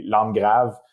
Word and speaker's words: Landgraves: Oui, oui, c'est un mot français, mais j'ai abandonné avec le temps parce que tout le monde le, prononce en anglais, mais en fait, ça, dit langue grave.